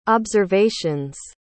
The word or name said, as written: observations